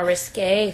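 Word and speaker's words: risqué: Risky things. More risque things, rather. – Risque. – Yeah. Thank you. – I had to stop for a second. I was like, that is how you say it, right? – Yeah.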